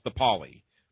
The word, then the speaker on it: DePauli: And that's spelled, and folks, just so you understand, that's Allison with two L's, and the surname is spelled DePauli, and pronounced DePauli. So just so you understand that.